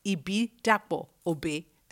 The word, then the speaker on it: Ibidapo-Obe: Okay, I can pronounce that better. Ibidapo-Obe. There you go. – Correct, yeah, that's all right.